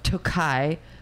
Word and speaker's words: Tokaj: So Tohai is actually spelled T-O-K-A-J, but I assumed it's pronounced Tohai. – Tokai. – Yeah. Okay. All right. See? So I was slightly off.